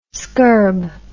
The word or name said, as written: scurb